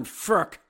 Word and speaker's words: Frk: They do have weapons on the power play, including Cannon Blaster Martin Furk. F-R-K, it's said exactly like it's pronounced.